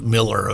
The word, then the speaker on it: Millar: It's like Miller over there is how they pronounce it.